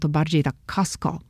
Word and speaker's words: Costco: I dzisiaj będziemy rozmawiali na temat Kostko.